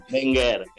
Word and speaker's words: Wenger: ¿Cómo se dice? ¿Alguien lo sabe? Arsène Werner. Wenger. Wenger.